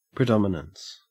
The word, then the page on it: predominance: Australian English